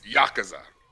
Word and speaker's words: Yakuza: True, but first off, if you're gonna say it, say it right. It's Yakuza.